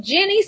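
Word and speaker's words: Jeni's: So Jenny Splendid Ice Cream,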